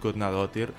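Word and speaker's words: Guðnadóttir: Gudnadotir, que és aquesta...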